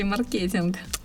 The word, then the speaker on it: маркетинг: Маркетинг или маркетинг? Мне больше нравится маркетинг, потому что согласно нормам русского языка, а, заимствованные слова из трех и более слогов, в них ударение идет на предпоследний слог. Инжиниринг, консалтинг, маркетинг.